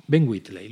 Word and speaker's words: Ben Wheatley: Ben Wheatley, lo he dicho bien, ¿verdad? – Sí, lo has hecho perfecto.